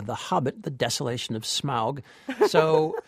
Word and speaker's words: Smaug: I always thought it was pronounced smog, like the Los Angeles pollution, but it's not. It's pronounced smaug.